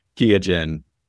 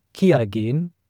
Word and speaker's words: Qiagen: And it was called Diagen back then, Diagen, and then they formed it to Qiagen, the German way to pronounce it, but internationally it's pronounced Qiagen.